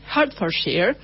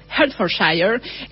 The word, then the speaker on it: Hertfordshire: Les cuento que Elizabeth McConkie nació en Broxbourne, Hertfordshire, eh, perdón, pronunció muy mal, Hertfordshire, que es un condado al norte de Londres en 1907.